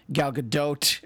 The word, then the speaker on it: Gal Gadot: But of course, if she isn't this amazing character, and Gal Gadot, as Agent Starling tells me I have to pronounce it, if she weren't so perfect for the part, the movie wouldn't work.